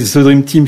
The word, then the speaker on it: The Dream Team: Écoutez, The Dream Team, parce que ça interroge tout le monde, oui. – Et ça se prononce comme ça s'écrit. – C'est ça. – Tout en... – En phonétique. – Tout en phonétique, Z-E-D-R-I-M-T-I-M.